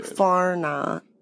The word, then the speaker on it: whānau: But whānau. – Whānau. – Fauna, which is what you said. – Whānau. – Whānau. No R.